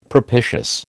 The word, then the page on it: propitious: American English